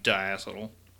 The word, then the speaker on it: diacetyl: And there are apparently two pronunciations, but I like diacetyl.